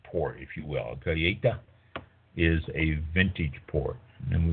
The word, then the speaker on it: colheita: A colheita, and it's spelled C-O-L-H-E-I-T-A, pronounced colheita.